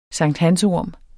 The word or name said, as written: SANKTHANSORM